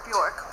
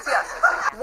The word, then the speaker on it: Björk: I mean, it's like Bjork and Björk, right? Everyone calls her Bjork, but her Iceland, her name is Björk. – Kelly looks shook. – It's pronounced Björk.